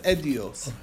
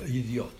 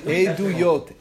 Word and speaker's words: Eduyot: Which is because Edios was testimonies, meseches Edios. – Okay, idiot. Okay. – Idiot, right. Not idiot. Edios, okay, you gotta say it right. It makes a big difference. – Okay, yeah, right. – Okay. Eduyot.